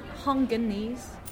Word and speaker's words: Hongkongese: Hongkongese, they call. – Is it really? – Yes, I read it on the newspaper. – They call us Hongkongese. – Hongge or Hongge? – Hongkongese.